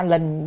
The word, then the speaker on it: linh: Chữ này có hai âm, đọc là Linh là lệnh. Chúng ta đọc trong câu Giả Sử cúng dường hàng sa thánh thì Giả Linh cúng dường hàng sa thánh. Chữ này là Linh, đọc là Linh. Linh là khiến cho, còn Giả Linh là Giả Sử. Còn đọc âm lệnh là mệnh lệnh là lệnh.